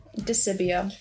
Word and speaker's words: DiSibio: How do you say your last name? – DiSibio. – Okay. No T.